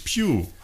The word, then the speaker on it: Pugh: Ja, die spricht sich Pugh.